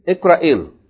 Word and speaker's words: اقرأي: Okay, but the long vowel we're not gonna pronounce it because it's followed by Hamzatul Wasl. So we're gonna say what? اقرأيل. Like short.